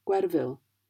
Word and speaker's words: Gwerfyn: One example is the letter F, as you find in the name Gwerfyn. As you can hear, the F is pronounced as a V in English.